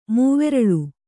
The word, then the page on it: ಮೂವೆರಲು: mūreral